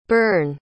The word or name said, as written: bern